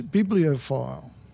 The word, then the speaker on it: bibliophile: The word is bibliophile. – Oh. – Or Phil. How do you say it? Bibliophile. Yes, I'll go with file.